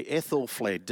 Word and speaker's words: Aethelflaed: Aethelflaed.